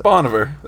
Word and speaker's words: Bon Iver: Bon Iver.